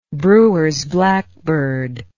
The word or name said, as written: Brewer's blackbird